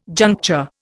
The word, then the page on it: juncture: Indian